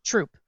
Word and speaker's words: Throop: Well, we have a town up near us called, it's spelled T-H-R-O-O-P. – T-H-R-O-O-P. – Yeah. – Throop? – Troop. – Just troop.